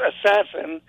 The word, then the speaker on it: ASASSN: The name is ASASSN. – What? – Which is pronounced assassin, which is something you really don't want to talk about too much.